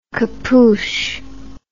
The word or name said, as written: capouch